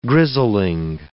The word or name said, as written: grizzling